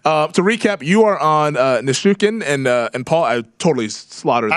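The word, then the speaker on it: Nichushkin: I think it's Nishukin. – Okay, Nishukin. I think Kelly's correct there. – There we go. – I'm going with the old school Russian pronunciation there.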